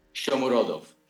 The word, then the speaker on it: Shomurodov: Shomurodov. – Yeah, Shomurodov.